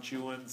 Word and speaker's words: Juan: The way, who cares how the Spanish pronounce it, I'm English, on Juan, so Juan rhymes with new one and true one and blue one and so on.